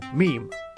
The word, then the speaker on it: meme: My meme comes from the suitable Greek root, but I want a monosyllable that sounds a bit like gene. I hope my classicist friends will forgive me if I abbreviate my meme to meme.